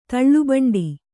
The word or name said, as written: ತಲೆಬಂಟ